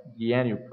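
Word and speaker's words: Dayenu: It's spelled in the English, it's D-A-Y-E-N-U, Dianu prayer.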